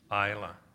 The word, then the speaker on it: Isla: And I know I'm going to say this wrong because it looks like Ilsa, but it's Isla, right?